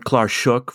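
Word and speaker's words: clàrsach: And you said what, Klarsach? Is that how you pronounce it? – So that's okay. Just the Gaelic word for harp. – Right. Sometimes I've heard Klarsuk for the wire-strung harp, but...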